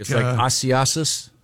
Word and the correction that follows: Isaias: That's very, very close. It's Hurricane Isaias. Isaias.